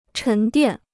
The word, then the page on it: 沉淀: chén diàn